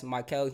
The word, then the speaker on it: Mikael: That's Mikael Jimenez.